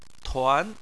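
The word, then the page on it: tuan: tone 2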